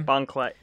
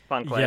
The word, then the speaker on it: Bon Clay: Am I saying that right? Is that the name? – Bon Clay. Bon Clay. – Yeah, Bon Clay.